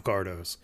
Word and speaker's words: Cardo: But the Cardos, is that how you say it? Cardo? – Yeah.